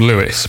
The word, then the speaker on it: Lewes: There's a town in East Sussex called Lewis, but it's spelled L-E-W-E-S.